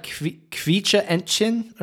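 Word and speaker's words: Quietscheentchen: Uh, isn't it like kvicha entchen or something? – Oh yeah, I guess that's pretty good. Entchen.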